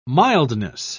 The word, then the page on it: mildness: British English